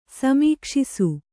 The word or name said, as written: ಸಮೀಕ್ಷಿಸು